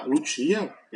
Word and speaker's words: Lucia: O personagem da ópera então é a Lucia, isso é a pronúncia em italiano, né, seria Lúcia, o Enrico,